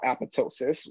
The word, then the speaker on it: apoptosis: or what the cell biologists call apoptosis, where there's a second silent P because people are Greek and Greeks have to make everything complicated because just look at democracy.